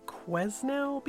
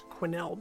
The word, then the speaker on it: Quesnel: Jessica Newman case I referred to uh, Quesnel, BC, and it's actually pronounced Quinnell, BC.